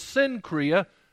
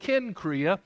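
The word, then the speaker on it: Cenchreae: And right across from Corinth was this place, Kenchrea. You might see that word and think it's Sincrea, but I've looked at it in the Greek, and I think it's better to pronounce it Kenchrea.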